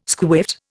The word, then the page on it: squiffed: Indian